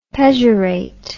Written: pejorate

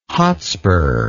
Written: Hotspur

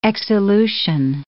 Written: exsolution

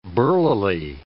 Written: burlily

